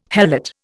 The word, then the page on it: helot: Indian